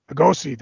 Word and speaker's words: Lugosi: And we even talk about, I can't even say his name, Lugosi, The Rise and Fall of Hollywood's Dracula. So Humanoids has got some Lugosi. – Good stuff. Lugosi, thank you. I knew I was muffling that one.